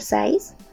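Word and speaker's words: PSY'S: Estos cinco temas faltantes fueron realizados por Size, así es, Size, o sea, así se pronuncia, la PSD, como si estuvieran escribiendo psicología.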